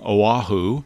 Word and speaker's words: Oahu: And in preparation, I brought a puzzle today based on the name Oahu, and oddly, it has three syllables and only four letters.